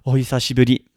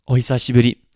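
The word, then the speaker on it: ohisashiburi (お久しぶり): But the word is ohisashiburi, ohisashiburi. Now, this is pronounced a little bit slower as ohisashiburi. It's the he sound. Often, this is mispronounced as oi or oya for some very, very strange reason that I can't quite wrap my head around.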